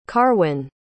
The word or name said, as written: carwyn